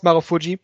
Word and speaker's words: Marufuji: So it's Marufushi, basically, but it's still pronounced Marufuji most of the times.